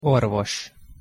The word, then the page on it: orvos: Hungarian